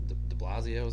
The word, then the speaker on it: De Blasio: But he goes, the mayor, De Blasio, is how you pronounce his name? – Yeah.